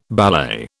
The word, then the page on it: ballet: British English